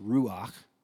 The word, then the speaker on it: ruach: In Hebrew, that word breath is pronounced ruach, and it's also synonymous with describing the Holy Spirit.